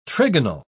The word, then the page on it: trigonal: British English